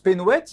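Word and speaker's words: Penhoët: Quand on parle de Pénouette, on prononce Pénouette, il y a une consonance bretonne.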